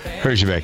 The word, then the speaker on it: Herjavec: That's how you pronounce his name. Herjavec.